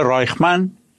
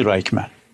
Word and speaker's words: رایخمن: یه دانشگاه برگزارش میکنه، یه دانشگاه خصوصی، دانشگاه رایخمن یا به تلفظی رایخمن، یه دانشگاه خصوصی در اسرائیل.